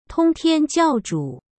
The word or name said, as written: tongtianjiaozhu